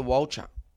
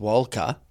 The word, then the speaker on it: Walcha: So anyway, mate, I go from Prime Cuts all the way to Walcher, which I've since found, by the way, is pronounced Walker.